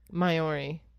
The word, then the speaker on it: Maori: I believe it's Maori. – Maori? I knew I was going to butcher that. – I don't know. – Maori. – That's how I've heard it, Maori.